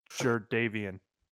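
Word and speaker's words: Jadeveon: Jarvavius Clowney. – Jardavian. – I was so close. – No, you're starting to pronounce like Tyrell. Jardavius? – I don't know, man. I just know it started with a J. I'm looking at it right now. It makes sense the way you pronounced it. Yes, I see this now. – Yeah, there's no S in his first name.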